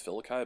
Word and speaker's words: Felici: His name's Filikai, but it's spelled like Felici, after he went down with an injury last season.